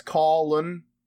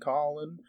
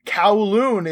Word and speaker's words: Colin: And by the way, that's actually how you pronounce my username. It's Colin. Just like how you pronounce my actual name, Colin. But the Kotaku pronouncer, I don't know, like announcer, I don't know why he said he's like, Kowloon is in for a surprise.